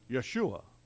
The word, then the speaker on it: Yeshua: Joshua, a word that is pronounced in Hebrew, Yeshua.